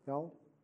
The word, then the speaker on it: Elle: Is it pronounced right, Elle? Yeah. Huh? Okay.